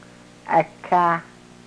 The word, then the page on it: ekah: yurok